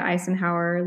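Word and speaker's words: Icenhower: And my last name, it's pronounced like the president Eisenhower, but it's spelled like vanilla ice, like ice, ice baby. It's ICE, N as in Nancy, HOWER.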